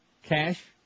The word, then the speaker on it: cache: Cache? How do you say that? – Cache. – Cache sounds like sachet.